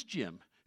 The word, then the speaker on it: Jim: My name is Jim.